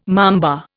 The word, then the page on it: mamba: Indian